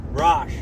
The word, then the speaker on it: Rosh: I am here with my buddy Garrett Rosh, right? – Rosh, that's right. – Rosh. Sounds like Rash.